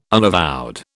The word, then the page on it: unavowed: British English